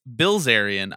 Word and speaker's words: Bilzerian: It sounds like you're calling KOC Kevin O. Blazarian. In fact, it's Bilzerian, I-L, not L-I.